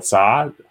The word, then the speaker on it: Zha: Or you can search my name, which is Carl Zha.